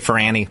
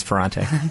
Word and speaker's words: Ferrante: Some people say Ferrani, but it's Ferrante.